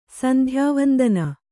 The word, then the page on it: ಸಂಧ್ಯಾವಂದನೆ: sandhyāvandane